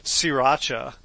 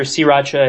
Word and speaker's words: sriracha: First came across this cookbook not because of my interest in plants, but because of my interest in sriracha. Am I saying it right, by the way? – Absolutely. I think I hear sriracha and sriracha a lot, and I think both those are just perfectly acceptable.